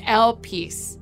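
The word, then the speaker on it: El Peace (ἐλπίς): It's El Peace. Isn't that wild? That is really how you say it.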